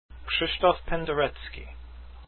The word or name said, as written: penderecki